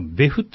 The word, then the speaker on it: 別府: はい、とか、ベフってとこもあるんですよ。別府と書いて、別府。